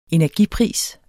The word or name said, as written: energipris